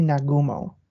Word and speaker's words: Nagumo: Nagomo? Nagumo? He, he is Vice Admiral Chuichi Nagumo.